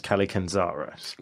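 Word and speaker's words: Kallikantzaros: So I read that as Kalikanzaros. – Well done. – These goblins... – Nailed it. Yeah, for once.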